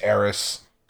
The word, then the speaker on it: Aeris: Are we going to pronounce it Aerith or Aeris? – It's Aeris.